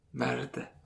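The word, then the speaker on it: merde: Okej, nästa ord är M-E-R-D-E. E. Merd. Merd.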